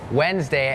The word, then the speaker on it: Wednesday: เห็นแล้วถอนหายใจเฮือกว่าเริ่มเลยก่อนเริ่มรายการพูดถึงคำว่าอะไรน้องๆจำได้ไหมคะ Silent letters ใช่ไหมคะนั่นก็คือตัวอักษรที่ไม่ออกเสียงแต่อย่างคำนี้ค่ะเป็นคำหนึ่งที่มี silent letters Wednesday it has that D W E D it's not wed Wednesday Wednesday D is silent